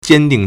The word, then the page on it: 坚定: 堅定; jiāndìng